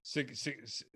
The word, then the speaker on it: Sienkiewicz: Can anyone help me properly pronounce Bill Sienkiewicz? – Sienkiewicz. – Sienkiewicz? – Sienkiewicz. – Sienkiewicz. Okay. – Yeah. – Stephanie's nodding. Yes. So we'll go with that.